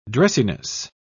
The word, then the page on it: dressiness: British English